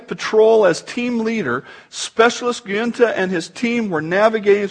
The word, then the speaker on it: Giunta: Giunta.